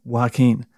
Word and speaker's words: Joaquin: And yes, that is how you pronounce it, because I looked it up before doing the podcast, because I knew some cunt would be like, oh, that's not how you say it. Well, there you go, right? Joaquin. And I know it doesn't look like it sound like that, because it begins with a J, but that's it, right?